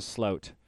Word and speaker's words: Sloat: Maybe it's Sloat Bucket. What do you think? Or do you think it's a sloat? – I would pronounce it sloat. You know, S-L-O-A-T.